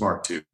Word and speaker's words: Mark II: Is it Mark II or MK2? – We pronounce it as Mark II.